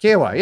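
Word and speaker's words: Kiawah: Is that right? Kiawa? Is that how you say it? For the PGA Championship. – Yep. – Kiawa, yeah.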